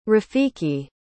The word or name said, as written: rafiki